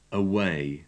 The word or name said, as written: away